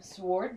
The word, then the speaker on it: sword: I like to pronounce the W. – Right. – Sword dancing.